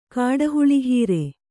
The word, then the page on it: ಕಾಡುಹುಳಿಹೀರೆ: kāḍu huḷI hire